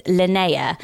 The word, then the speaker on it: Linnea: It's spelled Linnea. So it rhymes with Freya, but it's Linnea.